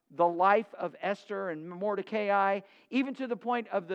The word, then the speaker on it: Mordecai: And I know some of you want to say it Mordecai. You just say it however you want. It's pronounced Mordecai.